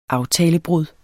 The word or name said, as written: aftalebrud